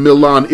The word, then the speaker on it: Milan: By the way, Milan is spelled M-I-L-A-N, and it looks like it should be pronounced Milan, as in Milan, Italy. But in Indiana, they pronounce it Milan.